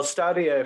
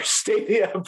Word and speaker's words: Stadia: Is it Stadia or Stadia? – Well, Stadia, if you're a classics major, Stadia probably for everybody else.